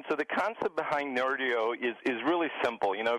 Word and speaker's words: Nerdio: I understand you just rolled out a new platform called Nerdio, or I'm not sure I'm pronouncing that correctly. – You are.